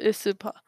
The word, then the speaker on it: aespa: Oh, that's how you pronounce it, aespa.